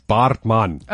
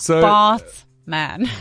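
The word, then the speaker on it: Baartman: Yeah, you also butchered that surname. It's Bartman. – Okay, well, right. – So Bartman.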